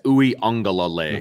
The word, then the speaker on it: Uiagalelei: That's, can you say it correctly? Because I don't want to disrespect his name. I just can't ever get it right. What's his name? – Yeah, it's Uiagalelei.